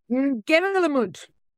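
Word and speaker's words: Ngerulmud: And I'm going to go Ngerelmud. – Sounds good. Yeah. My followers were trying to tell, the NG is similar to like the NG in Southeast Asia.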